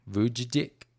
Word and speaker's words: Vujicic: Nick Vujicic? – Vujicic.